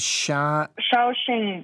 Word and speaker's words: Shaoxing: Shaoxing wine.